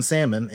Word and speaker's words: salmon: Just don't pronounce the L in salmon and I'm good.